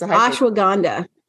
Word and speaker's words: Ashwagandha: It's Ashwagandha.